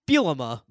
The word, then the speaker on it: Bielema: It's Bielema, by the way. You still hadn't got that right.